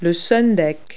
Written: sundeck

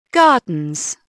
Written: gardens

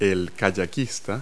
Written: kayaker

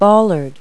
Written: bollard

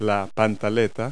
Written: briefs

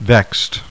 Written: VEXED

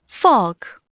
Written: fog

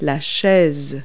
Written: chair

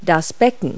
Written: cymbals

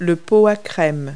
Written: creamer

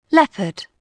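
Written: leopard

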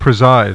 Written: preside

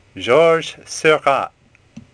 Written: Seurat

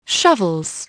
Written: shovels

